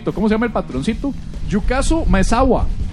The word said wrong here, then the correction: Yusaku Maezawa: No, perdón, Yusaku Maezawa. – Ah. – Perdón, lo dije mal.